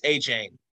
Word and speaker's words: Achane: I've heard Achane, Achane, Achane. – It's Achane. – All right, we're going to say Devin Achane, same name as my girlfriend, oddly enough, first name.